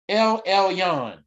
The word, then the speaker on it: El Elyon: The great El Yon Most High God, hallelujah. El, am I pronouncing that right? El Elyon, that's what I mean.